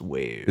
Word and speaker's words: WAV: I think it's pronounced wave. – Is it wave? – Yeah. – I thought it was a WAV file.